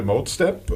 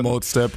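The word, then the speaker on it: Modestep: gerne anspielen wollte, Mode Step oder Mode Step, ich weiß gar nicht genau, wie sie ausgesprochen wird. Ich glaube Mode Step. – Wahrscheinlich Mode Step, ja.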